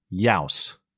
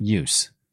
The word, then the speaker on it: Youse: Charles Youse, not use. Charles rhymes with house.